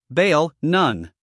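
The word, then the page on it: bail: uk beɪl; en-us beɪl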